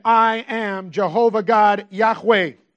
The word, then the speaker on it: Yahweh: So you get just the consonants that are then pronounced Yahweh.